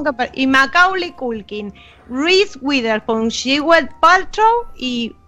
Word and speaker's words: Witherspoon: Reese Witherspoon.